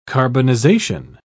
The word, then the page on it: carbonization: British English